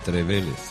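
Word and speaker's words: Trevélez: Además lleva acento en esa E, Trevele.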